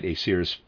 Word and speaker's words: Aesir: Readers aside, Aesir, I'm pronouncing it that way, is spelled A-E-S-I-R. Vanir, from before, is spelled V-A-N-I-R.